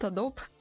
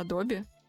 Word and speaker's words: Adobe: И я вот всегда говорила Adobe, Adobe, Adobe, а правильно это Adobe.